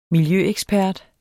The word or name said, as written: miljøekspert